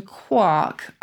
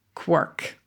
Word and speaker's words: quark: If you look in the Oxford English Dictionary, you can click on audio and get the pronunciation. So here you go. Quark. There you go. And in the US, quirk. There we go.